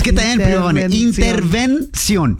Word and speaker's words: intervención: ¿Entrebanzón? – Intervención. – Entrebanzón. – No, es que te está mirando a ti, Shoboy, dijo panzón. – Prima Vani, can you say intervención? – Intervención. – ¿No? – Interversión.